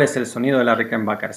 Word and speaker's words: Rickenbacker: Y para demostrarte cuál es la Rickenbacker, le, perdón, Rickenbacker se pronuncia correctamente, yo tengo una acá, mirá, no la podés ver, ahora la vas a escuchar.